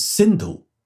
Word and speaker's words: Sindhu: The original name of that river is Sindhu. Now, ancient Persians, whenever they had this word with the letter S, they tended to pronounce it with an H sound. So they pronounced the name of the river as Hindu.